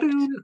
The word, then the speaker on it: Walloon: Let's talk about this method here, which is I'm assuming I'm going to pronounce it correctly, which is the Walloon method. – Is that correct? – Walloon? Yes, it is. Yeah.